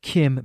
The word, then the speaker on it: Cim: And so I have some questions about this, but mostly because I want to know what Kim means. We should say that this is spelled C-I-M.